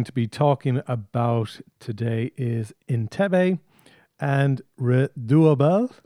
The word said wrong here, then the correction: Redoutable: Oh, Jesus. Redoutable. Oh, I'm even saying it wrong.